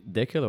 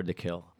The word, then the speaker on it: Dekel: You say Dekel or Dekel? – My parents spelled my name wrong. They spelled it D-A instead of D-E. Ooh. So I would tell people in America that my name was Dekel.